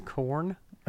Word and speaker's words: Quorn: I saw they started making this like new like vegan kind of like meat replacement product in the store, and it's spelled Quorn, and I was like, why are you dragging the Quran into this? And I found out it's pronounced corn. And I'm like, how would any rational person arrive to the conclusion that this spells corn? – Nobody.